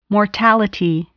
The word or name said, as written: mortality